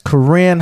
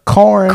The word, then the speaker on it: Corinne: Yes, Corinne. – I said it right. – Yeah. – I always want to say what I always call her. Corn. – Something corn like that. Hawthorne. But it is Corinne.